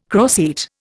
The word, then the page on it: croceate: Indian